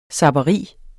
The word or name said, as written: zapperi